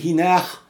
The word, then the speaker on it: Inagh: Alors Inar Valley, je dis Inar, je crois que ça se prononce ainsi, c'est I-N-A-G-H.